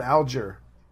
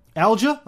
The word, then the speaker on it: Alger: I'm not sure, Alger or Alger? Alger Hiss, I think you pronounce it. – I've heard Alger. – Alger? Okay, we'll go with that then, as in Algeria.